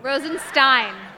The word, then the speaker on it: Rosenstein: On Friday, Deputy Attorney General Rod Rosenstein, am I pronouncing his name? – No. – Rosenstein.